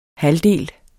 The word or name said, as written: halvdel